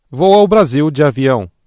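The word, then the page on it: avião: Brazilian Portuguese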